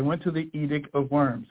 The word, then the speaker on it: Worms: It's pronounced Worms.